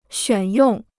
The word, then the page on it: 选用: xuǎn yòng